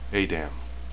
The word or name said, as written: adam